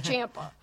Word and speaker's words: Ciampa: But it didn't help him beat Ciampa at all. – No. – Ciampa, I'm sorry. We all know how to say it now. It's Ciampa.